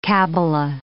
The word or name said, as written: cabbala